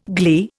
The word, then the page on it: glee: Indian